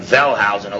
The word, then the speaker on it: Wellhausen: A lot of these, by the way, like Wellhausen, a lot of these guys, the W's are pronounced like V's.